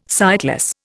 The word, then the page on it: sightless: Indian